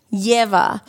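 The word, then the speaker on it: Ieva: Yeva. – So I constantly want to say Leva because I always see the I for an E. So it's I-E-V-A, Yeva.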